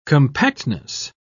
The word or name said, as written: compactness